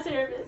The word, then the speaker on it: Cerberus: Okay, I always butcher the name, but Cerberus? – Yeah, Cerberus.